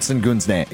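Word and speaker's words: Şengün: Sengun, a lot of people that are maybe casual basketball fans, unlike me, don't know a ton about Alperen Sengun's game, right? And is it pronounced Sengun? – Yes.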